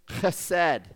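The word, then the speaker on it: chesed (חסד): And if there's anything you come away with today, come away with chesed, that is pronounced with a ch, because it's Hebrew, I guess.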